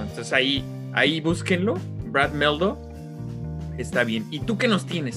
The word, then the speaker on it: Mehldau: que se, se escribe Brad como Brad Pitt y Meldo como M-E-H-L-D-A-U, Meldo.